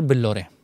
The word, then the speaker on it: Billore: तो मैंने अपनी चाय के ठेले का नाम रखा मिस्टर बिल्लोरे, मिस्टर बिल्लोरे, तो B I double L O R E. And अगर बिलियनर की भी स्पेलिंग लिखोगे और बिल्लोरे की, तो इट 60-65 सेम है स्पेलिंग, सी वो आई एन आर एड करना होता है. तो लोग मेरे को बिलियनर चाय वाला बोलते थे, कोई बिल्लो रानी चाय वाला बोलते थे.